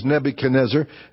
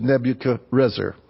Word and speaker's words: Nebuchadrezzar: There's a Jewish way to say it, which is Nebuchadnezzar. And then there's the Babylonian way to say it, which is Nebuchadrezzar.